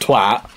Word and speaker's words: twat: And we're just like, first of all, it's pronounced twat. – Yeah. – And second... – Hard A.